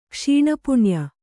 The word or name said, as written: ಕ್ಷೀಣಪುಣ್ಯ